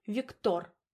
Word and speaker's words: Виктор: «Виктор» с ударением на «о».